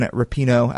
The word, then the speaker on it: Rapinoe: Good emphasis on the Rapinoe, not Rapinoe. – Rapinoe. Okay. So behind a couple of goals from Megan Rapinoe